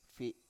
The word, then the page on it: fi7: Biak